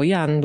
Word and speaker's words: Jan: Jego imię pisze się tak jak polski Jan. No i wymawiam to jako Jan, bo jest to reżyser, więc nie jest to żaden Jan ani inny John.